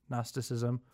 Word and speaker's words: Gnosticism: If you don't know what Gnosticism is, it's spelled with a G at the front. So if you want to even Google it. G-N-O-S-T-I-C, Gnostic, Gnosticism.